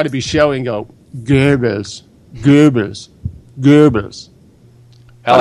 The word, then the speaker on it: Goebbels: Goebbels, O-E is an umlaut. That's not an English sound, so I don't expect you to get that right if you haven't studied German. I can say Goebbels because, and I'm not even pronouncing it particularly well. But like I said, the O with the umlaut, the two dots over it, is not an English sound. So English speakers can anglicize it to Goebbels if they want.